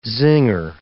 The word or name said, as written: zinger